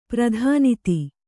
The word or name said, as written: ಪ್ರಧಾನಿತಿ